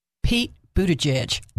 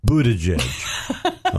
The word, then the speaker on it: Buttigieg: Pete Buttigieg. – Buttigieg.